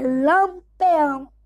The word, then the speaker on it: Lampião: Não, Virgulino Ferreira, vulgo Lampeão. Nota é Lampião. Conhecido como Lampião, mas na época se escreve com P.